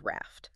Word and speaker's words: RAFT: It's an overarching system called RAFT, and that's an acronym, but it's pronounced simply as the word RAFT.